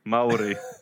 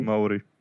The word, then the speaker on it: Maori: Maori. – Oh, you said it wrong. – Maori.